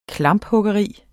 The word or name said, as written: klamphuggeri